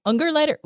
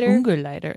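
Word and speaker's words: Ungerleider: I think it's Ungerleiter. – Ungerleiter. Yes. It doesn't have the umlaut, which you know not, what that is.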